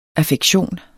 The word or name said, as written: AFFEKTIONERNES